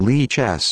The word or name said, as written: Lichess